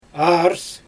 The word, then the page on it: ars: Xyzyl